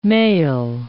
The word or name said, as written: mail